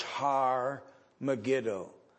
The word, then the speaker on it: Har Megiddo: Har in Hebrew is the word mountain. And it is usually translated that the H is silent. And so Armageddon is really the two Hebrew words, Har Megiddo. It's Mount Megiddo. But it refers to the Valley of Jezreel,